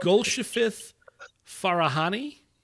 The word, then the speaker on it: Golshifteh Farahani: Uh, Golshifteh Farahani.